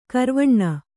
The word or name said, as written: ಕಾರವಾನ